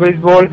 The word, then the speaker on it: béisbol: En México, el béisbol, pronunciado en México sin acento en la E, es el deporte más popular en las regiones norte y sureste.